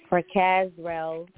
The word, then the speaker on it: Prakazrel: We are praying for Mr. Prac. Oh goodness, pronouncing his name properly. Prakazrel Mikel, better known as Pras, one third of the popular '90s group The Fugees.